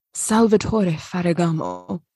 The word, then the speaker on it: Salvatore Ferragamo: Yeah, so my fashion story is that Salvatore Ferragamo.